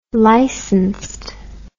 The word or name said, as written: licensed